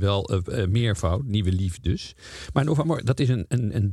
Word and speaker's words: Nova Mora: Eh, de partij heet Nova Mora. Nee, je moet het anders uitspreken. Nova Mora.